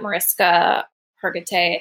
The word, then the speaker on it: Mariska Hargitay: but there was even a documentary that Mariska Hargitay, I think I butchered her name. – No, you got it right.